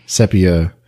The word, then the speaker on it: Sepia: You know, is it spelled S-E-P-I-A? – It is. – I think some people pronounce that sepia. – Like the color? – Yes. – Ah, that makes sense for some things I have to say then. – Now, I don't know for sure. That's how I pronounce it, is sepia.